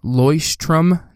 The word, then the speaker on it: Leuchtturm: So just in case you didn't hear that, it's Leuchtturm 1917.